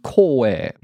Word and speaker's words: Koei: The proper pronunciation, though, because it's a Japanese term, would be Koei, which is usually spelled in katakana, so it's just like Koei.